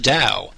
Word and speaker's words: Tao: You may be wondering, what is the Dow? Which is spelled T-A-O, but spelled like D-O-W, as in like the Dow Jones or something along those lines.